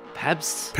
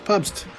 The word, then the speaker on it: Pabst: Is it Pabst? – Pabst. It's German. It's Pabst.